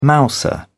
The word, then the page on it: mouser: British English